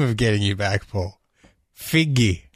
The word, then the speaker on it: Feige: He basically, I believe it's pronounced Figgy. – Figgy? – Figgy.